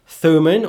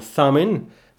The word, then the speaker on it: Thummim: Thummim or Thummim, this magical contraction.